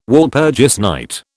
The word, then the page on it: Walpurgis night: British English